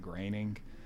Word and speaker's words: Groening: It's Groening. Even though it's G-R-O-E-N-G.